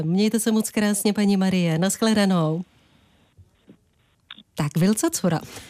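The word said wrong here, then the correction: Vilcacora: Správně se to vyslovuje Wilka Cora.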